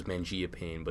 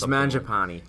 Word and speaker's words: Mangiapane: I always said it was Mangia Payne, but Dunleavy was saying Mangiapane or something. – It's Mangiapane.